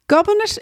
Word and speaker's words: Gobnait: Gobinet is spelt G-O-B-N-A-I-T, and Gobinet is an ancient Irish name meaning bringer of joy.